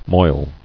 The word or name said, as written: Moiling